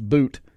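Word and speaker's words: Bout: It's B-O-U-T, I believe. I believe it's pronounced boot.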